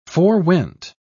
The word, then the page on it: forewent: British English